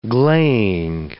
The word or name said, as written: gleying